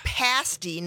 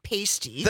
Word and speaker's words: pasty: And it's pasty, not pasty.